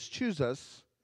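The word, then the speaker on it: Chuza: Her husband is Chuzas, is how you'd pronounce that.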